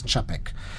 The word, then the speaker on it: Čapek: Escreve-se C-A-P-E-K, mas pronuncia-se Čapek.